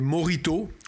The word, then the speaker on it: mojito: Et en général, dans les cocktails, vous allez avoir les plus fréquents en France, ça va être les mojitos. On dit mojito, on remplace le H par un R quand on le prononce, un mojito.